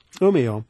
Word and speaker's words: Umeå: He's also a guest professor at the Swedish University of Agricultural Sciences at Umeå, Sweden. I think I've got the pronunciation. – Umeå.